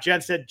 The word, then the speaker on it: Jenstad: I am Scott Jenstad, J-E-N-S-T-A-D on my last name.